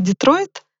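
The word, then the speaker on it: Détroit: И так до меня дошло, что слово «Детройт», город Детройт, по-французски это «Детро».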